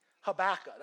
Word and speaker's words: Habakkuk: It's H-A-B-A-K-U-K. I was like, it's crazy. Habakkuk, that's how you say it.